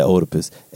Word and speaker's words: Oedipus: How do you say it? – Oedipus. – I said Oedipus. Oedipus, thank you.